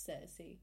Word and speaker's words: Cersei: It's Cersei.